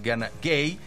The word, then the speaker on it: Gana Gueye: Lamine Camara, porque también tiene su Lamine allá en Senegal, está en la media cancha junto con Gana Gueye, la pronunciación es Gana Gueye.